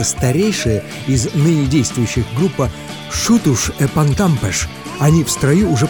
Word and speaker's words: Xutos: А произносится «шутуш» практически одинаково.